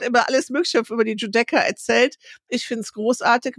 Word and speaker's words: Giudecca: Du hast es fast richtig ausgesprochen, Giudecca,